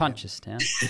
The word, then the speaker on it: Punchestown: Hey, overnight, I saw in the UK a maiden hurdle at Punchestown. I'm not sure if I pronounced that correctly. – I don't think you did. – Punchestown. punches down. – Definitely you pronounce that right.